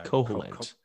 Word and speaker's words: Koholint: Going somewhere, and he ends up shipwrecked on a small and quirky island of Koholint. I don't know if I've ever known how to say it. – Yeah, Koholint.